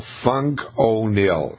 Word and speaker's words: Fung-O-Nil: On the man-made side, the fungicide is called Fung-O-Nil, and it's spelled F-U-N-G-O-N-I-L, Fung-O-Nil.